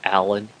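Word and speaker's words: Alan: You'll also see right after my name is my Twitter handle, which is at Paul Allen Cliff.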